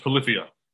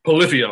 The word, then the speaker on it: Polyphia: And it's pronounced Polyphia. I just remembered. – Polyphia. I had a feeling it was going to be, be something like Polyphia. Polyphia. – No, Polyphia.